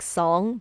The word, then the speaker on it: Song: In northern Song, Song, it's spelled S O N G like Song, but it's Song Dynasty.